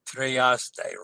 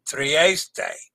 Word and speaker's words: Trieste: Trieste. Remember, pronounce the E's and A-Y as they. Trieste.